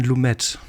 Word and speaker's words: Lumet: Ich habe mich extra belesen und Recherche betrieben und das heißt tatsächlich Sidney, also sogar mit I wie ausgesprochen und dann Lumet.